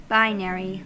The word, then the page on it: binary: American English